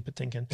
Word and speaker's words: Patinkin: The way that you say his name is driving me crazy. – How do you say it? – Mandy Patinkin. – Okay, Mandy Patinkin.